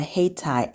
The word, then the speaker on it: Hayti: Haytie app.